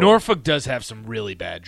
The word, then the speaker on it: Norfolk: The first time I ever anchored, it was talking about Norfolk, and I said it that way. But it's pronounced Norfolk.